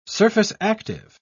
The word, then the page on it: surface-active: British English